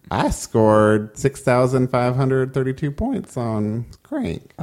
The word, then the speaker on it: Qrank: I want to say it's pronounced Crank, but it's spelled Q-R-A-N-K.